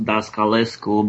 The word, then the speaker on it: Dascalescu: My last name is pronounced Dascalescu, but I'll take any variations thereof.